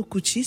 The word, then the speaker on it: Cuxi: Ici de parents originaires pardon, du Cap-Vert, Lissandro Cucci, si je le dis bien, tu l'as dit parfaitement.